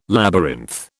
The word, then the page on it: labyrinth: British English